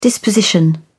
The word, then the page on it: disposition: British English